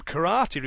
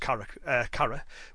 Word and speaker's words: kara: It's even been suggested that the name karate reflects this combination by using the character for Chinese, pronounced kara, which was later substituted with the character for empty, which is also pronounced kara.